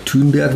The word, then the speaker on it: Thunberg: ich glaube Greta Thunberg oder Thunberg, wie sie richtig ausgesprochen wird, die ist ja im Moment gerade so, wie sie sagen, das ist ja gerade so ein Hype.